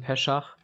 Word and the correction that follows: Pesach: jetzt Pessach, pardon, Pessach. sehr gut.